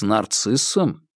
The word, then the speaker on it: Нарцисс: «С Нарциссом?» — машинально переспросил Вербин. – «Нет, именно с Нарцисс. Ударение на «а».